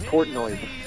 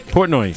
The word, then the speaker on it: Portnoy: Pronouncing his last name, it's pronounced Portnoy. – Portnoy.